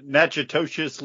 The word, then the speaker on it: Natchitoches: Can somebody pronounce that? – Natchitoches, Louisiana. – Natchitoches, bringing some meat pies with them.